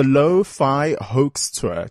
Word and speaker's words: Lo-Fi Hoax: It's the Lo-Fi Hoax Tour. Did I pronounce it correctly? – Correct, yeah. – There we go. And it's L-O, so for the low, F-I for the fi, and H-O-A-X, of course, for the hoax tour.